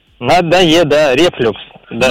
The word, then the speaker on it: рефлюкс: Согласно данным русских орфографических словарей, в данном слове ударение всегда ставится только на второй слог. Поэтому правильно говорить рефлюкс.